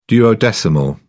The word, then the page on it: duodecimal: British English